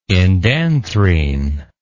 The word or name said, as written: indanthrene